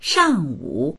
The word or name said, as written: shang4wu3